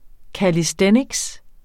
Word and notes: calisthenics: kaliˈsdεnigs; kalisˈθεnigs

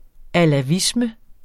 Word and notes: alawisme: [ alaˈvismə ]

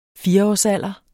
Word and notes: fireårsalderen: [ ˈfiːʌɒs- ]

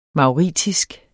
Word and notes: mauritisk: mɑwˈʁiˀtisg; mɑwˈʁitisg

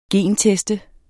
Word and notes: genteste: ˈgeˀn-

